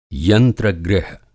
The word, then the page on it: यंत्र-गृह: Hindi